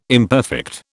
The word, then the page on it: imperfect: British English